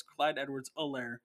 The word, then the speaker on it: Edwards-Helaire: And actually, it's Clyde Edwards-Helaire, apparently. I just heard on the broadcast. It is, the H is silent, so it's Clyde Edwards-Helaire.